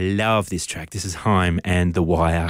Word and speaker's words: Haim: Now, I'm pretty sure it's pronounced Haim. I have been calling them Haim for the longest time, but someone corrected me not that long ago.